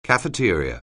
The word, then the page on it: cafeteria: anglais